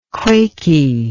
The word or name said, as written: quaky